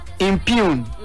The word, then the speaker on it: impugn: Impune. Where near come as him? – Yeah. – And near area. I-M-P-U-G-N. Impune. J-N-O is silent. You don't pronounce it.